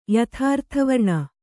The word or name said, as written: ಯಥಾರ್ಥವರ್ಣ